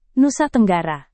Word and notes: Nusa Tenggara: Indonesian

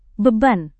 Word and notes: beban: indonesian

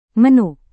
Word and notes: menu: Indonesian

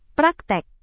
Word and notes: praktek: Indonesian

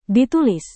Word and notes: ditulis: Indonesian

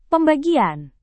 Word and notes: pembagian: Indonesian

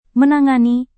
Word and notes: menangani: Indonesian

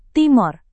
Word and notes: timor: Indonesian

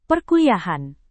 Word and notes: perkuliahan: Indonesian

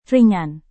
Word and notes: ringan: indonesian

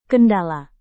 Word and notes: kendala: indonesian

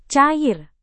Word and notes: cair: Indonesian

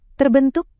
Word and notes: terbentuk: indonesian

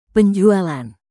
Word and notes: penjualan: indonesian

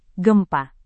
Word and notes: gempa: Indonesian

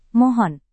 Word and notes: mohon: Indonesian

